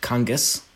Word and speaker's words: Kongos: So Danny Kongos, K-O-N-G-O-S, sometimes pronounced Kongos. – Yes, yeah. Well, we don't fight that battle anymore, but yeah, it's just Kongos. Oh, I'm sorry. It's much easier. Okay. – What's that, Kongos? – Kongos. – Kongos, yeah, exactly. Yeah. Easier. You spell it, yeah, say it like it looks.